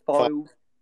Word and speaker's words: Fylde: Fylde.